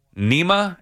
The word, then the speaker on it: Nejme: Sochi, senior director of football strategy, and Greg Nema, and I'm assuming the J is silent. N E J M E, whatever.